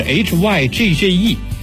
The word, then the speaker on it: hygge: 没错，那就是 HYGGE，就是 HYGGE，在丹麦语中啊发音就是念我刚才那个 HYGGE。这是丹麦人啊常把 HYGGE 挂在嘴边，也是丹麦文化的核心之一。 HYGGE 呢一般被翻译为舒适，但其实啊这是一个只能用心体会无法准确翻译的词语。如果一定要我们给 HYGGE 下一个定义，